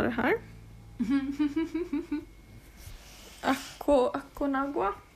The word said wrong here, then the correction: Aconcagua: Det högsta berget i Anderna är då Aconcagua. – Aconcagua.